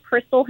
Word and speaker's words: Chrystal: It's spelled CHRYSTAL, Crystal Hammer.